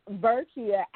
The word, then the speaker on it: Verkia: I'll say, hi, my name is Verkia, and they'll go, oh, hi, Verkia, or hi, Nakia, and I'm like, no, it's Verkia as in with a V, like victory.